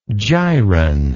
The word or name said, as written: gyron